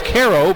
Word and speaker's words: Quero: Now, his name is Caro, but it's spelled Q-U-E-R-O.